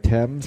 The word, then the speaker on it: Thames: If you go to his Twitter feed, at Doherty Drew, he does a little video of the River Thames and... That's what it is, right? Thames? – Thames. It's spelled like T-H-A-M-E-S.